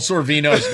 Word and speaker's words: Sorvino: You could teach Mini Sorvina some new words. – You just absolutely butchered Paul Sorvino's name.